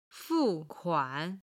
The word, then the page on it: 付款: fu4kuan3